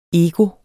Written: EGOETS

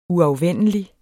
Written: uafvendelig

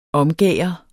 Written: omgærde

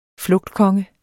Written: flugtkonge

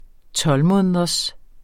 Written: tolvmåneders

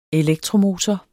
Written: elektromotor

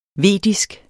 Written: vedisk

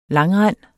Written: langrend

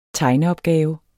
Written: tegneopgave

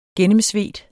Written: gennemsvedt

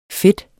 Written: fedt

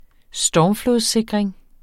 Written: stormflodssikring

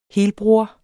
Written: helbror

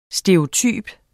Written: stereotyp

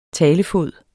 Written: talefod

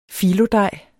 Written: fillodej

